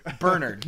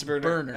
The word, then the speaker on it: Bernard: Bernard. No, it's Bernard.